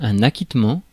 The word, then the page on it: acquittement: France; [a.kit.mɑ̃]